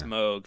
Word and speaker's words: Moog: I was just gonna correct you if you had said Moog. That was sort of my one, the one thing I had in my back pocket there. – Sorry, I got it right. Yeah. – It's Moog, folks.